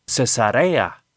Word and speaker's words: Cesarea: Existe la palabra cesárea. Una cesárea es una operación que se utiliza a veces en partos difíciles, y esa palabra lleva acento en la antepenúltima sílaba. O sea, que debe haber un tilde arriba de la primera A. Y el nombre de estas dos ciudades bíblicas no lleva tilde. Así que se pronuncia Cesarea.